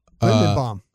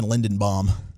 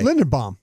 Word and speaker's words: Linderbaum: Is that the wrong way to say his name? You were saying Lindenbaum yesterday during the pregame show.